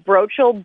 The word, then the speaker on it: Brotschol: So my first name is spelled H-I-L-L-E-R-Y. Last name is Brochol, B-R-O-T-S-C-H-O-L.